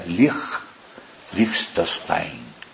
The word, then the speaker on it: Liechtenstein: Se pronuncia Liechtenstein. Liechtenstein se escribe.